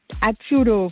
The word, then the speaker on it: Azzurro: Und zwar wünsche ich mir Azzurro von Adriano Celentano. – Ach, wie schön. Und Sie haben das so schön ausgesprochen. Können Sie Italienisch? – Nö. – Aber es klang echt authentisch.